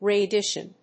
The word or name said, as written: readdition